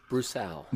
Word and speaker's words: Broussal: Now I want to say your name right, Broussal.